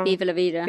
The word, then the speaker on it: Viva La Vida: But he played Diva La Vida, the Diva, I always say it wrong. – Viva La Vida.